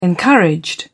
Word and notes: encouraged: British English

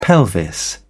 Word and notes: pelvis: British English

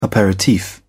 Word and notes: aperitif: British English

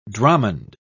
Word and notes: dromond: British English

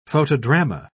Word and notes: photodrama: British English

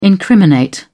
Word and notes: incriminate: British English

